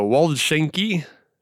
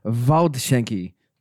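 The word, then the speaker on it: Waldschänke: This one's a mouthful for not being German, but it's a Waldschenke, and it's cider and coffee. So that's a fun place, but that's in Denver. – In beautiful Denver, Colorado. And actually, it is pronounced Waldschenke.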